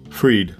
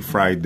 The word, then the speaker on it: Fried: Freed is freed. Yeah, here's how he pronounces his name. I know you say fried there, it looks like, but he calls his name is Freed.